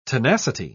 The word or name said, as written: tenacity